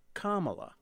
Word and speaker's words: Kamala: Harris's first name is pronounced Kamala. It's derived from ancient Sanskrit and is a nod to her Indian heritage.